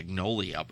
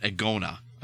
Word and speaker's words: Agonia: They're actually called Agnolia. I was about to say Agnolia, but that's not it. Agonia.